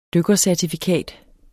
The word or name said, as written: dykkercertifikat